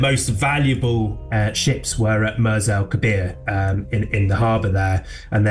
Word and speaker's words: Mers El Kebir: For people who've never heard of this story, just tell us then what actually happened at, is it Mers El Kebir? Is that how you say it? – Mers El Kebir, yeah, in Algeria.